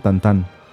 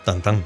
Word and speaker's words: Tintín: Y lo otro es, que, dato curioso, en Francia no le dicen Tintín, en Francia le dicen Tantán. Tantán.